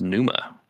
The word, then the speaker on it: Pneuma: Oh, Noma by Tool. – I think it's pronounced. That's like, okay, that's fine. All right. Yeah. – What's it pronounced as there, I think it's Pneuma, right? – Whatever, it's the same shit.